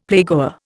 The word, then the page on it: playgoer: Indian